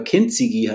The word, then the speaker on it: Kintsugi: Ich kannte Kintsugi, da schreibt man Kintsugi, also mit U hinten, aber Kintsugi heißt es eigentlich korrekt ausgesprochen, kannte ich schon, weil ich zweimal das Glück hatte, in Tokio für kurze Zeit arbeiten zu dürfen, beruflich mit der Agentur.